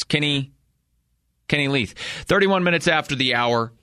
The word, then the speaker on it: Leuth: We've been saying it wrong. Quarterback for the UNC Bears, Kenny Luth, L-E-U-T-H. Coach Lamb has said as much. It's Kenny Leith. It's Kenny Leith.